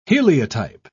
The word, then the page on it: heliotype: British English